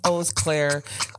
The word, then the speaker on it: Eau Claire: I went to a music festival in Eau Claire, Wisconsin, and I said Eau Claire like 8 times, and it's pronounced Eau Claire, and I sound like a goober, but yeah, it's pronounced Eau Claire.